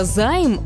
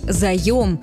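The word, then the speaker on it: заём: А вы знаете, что слова «займ» в литературном русском языке нет? Правильно говорить «заем». Именно такой вариант дают словари. 42-я глава Гражданского кодекса Российской Федерации тоже называется «заем и кредит».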